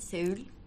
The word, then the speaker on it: Seoul: Can you help me to pronounce the name of the city, Seoul? – Seoul.